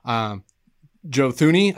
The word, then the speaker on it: Joe Thuney: Top free agents, Joe Thuney, I hope I'm saying that right.